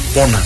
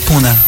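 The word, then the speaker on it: Pune: Aí em 2011, iniciou sua trajetória de bailarino do grupo Pona. É essa pronúncia mesmo? – Puna.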